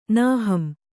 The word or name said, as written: ನಾಹಂ